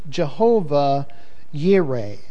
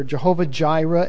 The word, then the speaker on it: Jehovah Jireh: And Abraham called the name of the place Jehovah Yireh, as I've listed it here, or Jehovah Jireh, as it's sometimes pronounced.